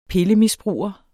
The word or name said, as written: pillemisbruger